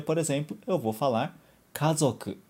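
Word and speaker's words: 家族: Kazoku.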